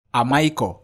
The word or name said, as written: AMAIKOR